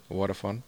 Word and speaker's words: Vodafone: So you're working at Vodafone. I think that's how it's pronounced. – Vodafone.